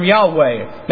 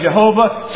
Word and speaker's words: Yahweh: It's the Hebrew term Yahweh. Now, it's spelled as Y-H-W-H. All right, Yahweh is one of the pronunciations. Some say Jehovah, some say Yehovah, some say a couple of different things.